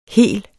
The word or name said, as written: hel